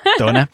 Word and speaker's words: Madonna: Madonna. Og øh, det hedder Material Girl, og øh, det kommer her. Det var Madonna, var det ikke korrekt udtalt? – Jo, det var nydeligt.